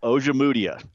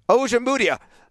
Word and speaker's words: Ojemudia: And Michael Ojemudia, or how do you pronounce that? Ojemudia, I think is how you pronounce it. Ojemudia. – Ojemudia. Third time's a charm.